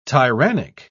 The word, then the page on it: tyrannic: British English